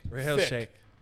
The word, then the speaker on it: Rahil Shaikh: My last name, Milkshake. – Milkshake. – Yeah, not Milkshake, like Shake. – Rahil Shake.